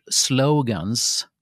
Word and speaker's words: slogan: Eh, Eh, inte nog då med att man uttalar det med diftong som om det var på engelska då. Jättemånga gör det här, jag vet det. Men jag tycker att det är fel för slogan heter det, är, liksom det rekommenderade uttalet i Svenska Akademiens ordlista.